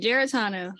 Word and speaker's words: Giaratano: You got it right, Giaratano.